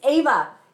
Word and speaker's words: Eva: Later in the show, we've got Eva Vlaardingerbroek, okay? I think I say it right on the interview, but she's amazing. Oh, and I said it wrong already. It's Ava.